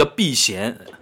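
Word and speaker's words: Beckham: 呃，一个什么朗拿度、朗拿电奴那那个，我老是看球辰光朗拿度，就罗纳尔多嘛，叫朗拿度，贝克汉姆叫避嫌，是按照广东闲话发音来的。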